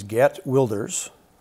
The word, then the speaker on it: Geert Wilders: His name is Geert Wilders. – That's a Dutch pronunciation. We can all learn from that.